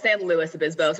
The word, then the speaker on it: San Luis Obispo: Was it part of your program at Cal Poly San Luis Obispo? Is that how you say it? – Almost San Luis Obispo. So it's a little less fancy.